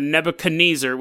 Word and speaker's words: Nebuchadnezzar: That's set to the Twilight Zone music, and it's like, everyone who wronged me will be trapped on the Nebuchadnezzar, which is not how you pronounce it, it's Nebuchadnezzar, but all the souls who wronged me, like Keanu Reeves, will be trapped on the Nebuchadnezzar, floating through October 18th, 2000, forever.